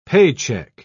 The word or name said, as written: paycheck